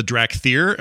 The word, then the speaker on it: Drakthyr: In particular, players are going to be able to start a new race called the Drakthyr. Am I saying that right? I've been saying that way all day. Is that right? – Yep. – Okay, easy enough.